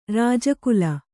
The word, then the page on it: ರಾಜಕಲೆ: rāja kale